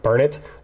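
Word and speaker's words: Burnet: It's it's it's B U R N E T. It looks like Burnett, but it's pronounced Burnett. That whole street looks like it's straight out of the 70s.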